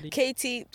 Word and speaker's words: Katie: Do you prefer Katie or Katie? – Katie. Katie's fine. I just spell it differently when I'm performing, I guess. Because I haven't had a nickname in like forever, so Katie is fine.